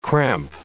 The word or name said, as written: cramp